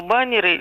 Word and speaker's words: баннеры: Множественное число баннеры, либо нера. – Баннеры. Баннеры, потому что это слово еще совсем не обрусело, и перехода ударения на окончание в формах множественного числа в нем не произошло.